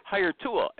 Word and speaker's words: Hiretual: Hire Tool, as Jerry always reminds me, it's spelled like this, HireTual, the super-duper sourcing tool that everybody raves about.